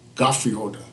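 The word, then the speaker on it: Geoffrey Holder: We all call him Jeffrey Holder. – Right. – Godfrey Holder, that's his real pronunciation.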